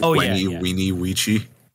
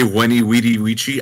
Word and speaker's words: veni vidi vici: Did you know veni vidi vici is pronounced weeny weechi? Because V does not exist in Latin. That's another thing, that if you say weni, weedy, weechi, I hate you. I don't care that that's how it's pronounced.